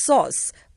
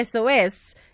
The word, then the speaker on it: SOSS: It's pronounced sauce, but it's pronounced sauce, but we can say SOS, even though it's spelled SOSS.